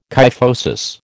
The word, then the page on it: kyphosis: American English